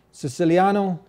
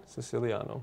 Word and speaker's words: Siciliano: Siciliano. – Siciliano. – So it rhymes with Italiano.